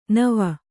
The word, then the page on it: ನೆವ: neva